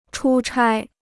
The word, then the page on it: 出差: chū chāi